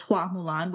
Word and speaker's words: Hua Mulan: Mulan's original name in the legend was Hua Mulan, which is the Mandarin pronunciation. Fa Mulan is the Cantonese pronunciation, which is what we get in the Disney in the animated version.